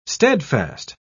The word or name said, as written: steadfast